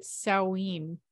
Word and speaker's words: Samhain: I actually think you pronounce it Samhain. – Oh, well. – Right. That old Irish. Old Irish doesn't actually sound anything like the way it's spelled.